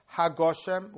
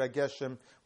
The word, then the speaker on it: Hageshem: Should it be Mashvaruch Amoyed HaGoshem with a comets, or should it be Mashvaruch Amorid Hageshem with a sego? And he paskins that you should say Mashvaruch Amorid Hageshem with a sego.